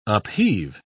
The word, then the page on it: upheave: British English